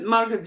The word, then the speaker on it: Margrét: You pronounce it Margrét Jóns. It's the same.